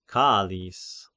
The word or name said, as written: Chaallis